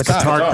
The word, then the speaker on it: Qatar: In Qatar. – It's Qatar. Qatar. They said you can pronounce it any way you like, as long as they money keep flowing in.